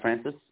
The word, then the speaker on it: Francis: D-O-R. – Yeah. – I-A-N. – Yeah. And last name? – Francis, F-R-A-N-C-I-S.